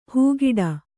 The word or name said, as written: ಹಾಗಡ